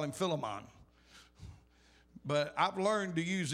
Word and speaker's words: Philemon: The book of Philemon, verse 9. I know some of y'all look at me like, that's not how it's pronounced. That's exactly how it's enunciated.